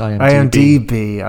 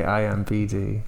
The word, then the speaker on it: IMDb: It's IMDb. I say IMBD.